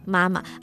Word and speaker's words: 妈妈: Mama. Properly in Chinese, the pronunciation is supposed to be mama. So the second tone is actually a light tone.